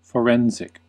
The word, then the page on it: forensic: fəˈɹɛn.zɪk; fəˈɹɛn.sɪk; American English